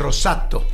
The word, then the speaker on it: rosato: Pero italiano se dice rosato.